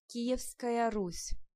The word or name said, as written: Kievrus